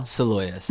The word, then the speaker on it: Salois: Soloyas. – Soloyas. – Soloyas is the French pronunciation. – Soloyas. Yeah. – Which I feel like my family kind of Americanized it.